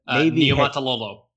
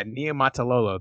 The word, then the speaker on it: Niumatalolo: And I'm gonna butcher his name, but I'm gonna try. – Niumatalolo. – Yeah, Niumatalolo. There you go.